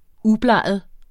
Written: ubleget

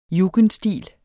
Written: JUGENDSTIL

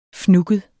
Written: fnugget

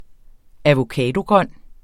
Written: avocadogrøn

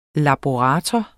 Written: laborator

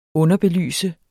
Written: underbelyse

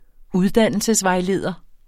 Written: uddannelsesvejleder

